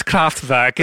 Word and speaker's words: Kraftwerk: This is Kraftwerk, is the name of the band, okay? Say it properly.